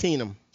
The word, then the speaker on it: Keenum: Keesum. And how do you pronounce his name? – Case it's Keenum.